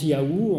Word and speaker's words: Yahou: Alors quand il y a marqué le Seigneur en capitale comme ça, vous savez que c'est Yahou, c'est le nom de Dieu qui se prononce Yahou, on le sait, puisqu'il y a des noms qu'on appelle, je sais pas, Théophore, hein, c'est-à-dire comme Nathan Yahou, c'est Dieu donne.